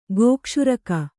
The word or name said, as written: ಗೋಕ್ಷುರಕ